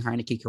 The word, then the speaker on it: Heinicke: But I think pairing Fitzpatrick and Heinicke, and am I pronouncing Heinicke correctly? – Yeah.